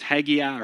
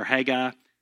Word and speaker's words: Haggai: There's two ways you can pronounce that I looked up. It's Haggai or Haggai. I like Haggai better.